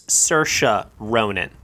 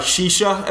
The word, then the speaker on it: Saoirse Ronan: And while I'm at it right now, it's Saoirse Ronan. – Saoirse Ronan. Shisha, as they pronounced it at the Golden Globes.